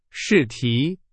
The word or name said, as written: 试题